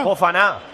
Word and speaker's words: Fofana: Fofana.